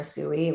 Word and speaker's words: Usui: So what I'm hearing is that, you know, after this, I want to say popularization almost in the 1800s when Dr. Usui, am I saying that correctly? – Cool.